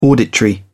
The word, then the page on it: auditory: British English